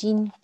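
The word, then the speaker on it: 晶: Jin is pronounced Jin.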